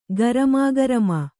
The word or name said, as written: ಗರಮಾಗರಮ